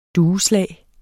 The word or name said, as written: dueslag